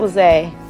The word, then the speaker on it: LaZae: Yes, Felice LaZae.